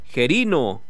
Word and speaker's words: Gerino: No tiene acento en Gerino, no. Es Gerino. Si fuera Gerino, tuviera acento en la O. Si fuera Gerino, tuviera acento en la E. Pero no, no tiene acento. No tiene acento. Entonces es Gerino.